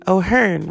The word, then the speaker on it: O'Hearn: O'Hearn rhymes with concern.